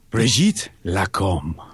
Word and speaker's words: Brigitte Lacombe: Bienvenue, Brigitte Lacombe. – Yeah. Brigitte Lacombe. – Brigitte Lacombe.